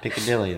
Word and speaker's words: Piccadilly: Piccadilly, nah, I think you're good.